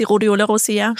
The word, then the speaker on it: Rhodiola rosea: Die Rhodiola rosea.